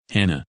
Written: Hannah

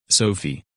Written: Sophie

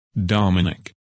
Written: Dominic

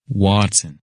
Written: Watson

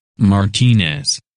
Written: Martinez

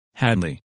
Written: Hadley

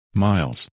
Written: Miles

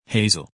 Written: Hazel

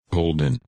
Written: Holden

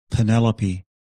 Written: Penelope

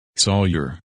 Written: Sawyer

